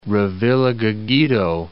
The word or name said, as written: Revillagigedo Island